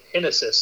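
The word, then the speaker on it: Génesis: He's pronouncing it Genesis, so it's going to take some getting used to for me.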